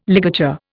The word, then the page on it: ligature: Indian